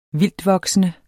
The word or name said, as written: vildtvoksende